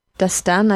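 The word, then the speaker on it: Dastan: And the king did a great act of service by, when he saw this boy, our main character, Destan. Mm-hmm. Am I pronouncing that right? I feel like I'm mispronouncing it every time. – Destan, I think, is right. Okay. That's the way they said it in the movie.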